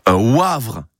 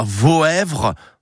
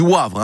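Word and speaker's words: Woëvre: Et pour savoir que ce secteur de la Meuse se prononce Wavre et pas Voèvre, c'est W-O-E-V-R-E, il n'y a que chez nous que ça se dit Wavre.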